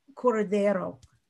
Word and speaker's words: curandero: So traditionally, what we're talking about is that there is a shaman, there's a curandero is how you say it.